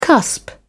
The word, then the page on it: cusp: British English